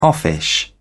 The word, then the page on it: offish: British English